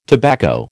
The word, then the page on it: tobacco: American English